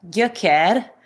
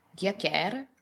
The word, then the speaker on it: Gyökér: If I was to say it properly, it is a Hungarian word. It means root in Hungarian. So we talk about the root of the scalp of the hair. It's pronounced Gyökér.